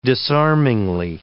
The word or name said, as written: disarmingly